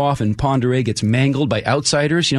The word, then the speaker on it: Pend Oreille: The county name is actually two words, P-E-N-D, and then O-R-E-I-L-L-E. It's not said the way it looks, right? – Yeah.